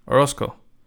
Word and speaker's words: Orozco: If you say it fast enough, that's actually my last name, Orozco. But of course, spelled differently.